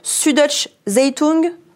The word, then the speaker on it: Süddeutsche Zeitung: Vous êtes aussi correspondant allemand basé à Paris et vous travaillez pour le, alors pardonnez-moi la prononciation, Süddeutsche Zeitung.